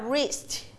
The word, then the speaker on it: wrist: wrist w ไม่ออกเสียงนะคะออกเสียงตัว r ทันทีนะ